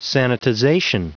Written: sanitization

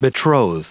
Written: betroth

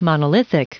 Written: monolithic

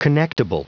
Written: connectible

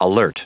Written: alert

